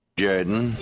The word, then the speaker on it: Jordan: It's pronounced Jordan.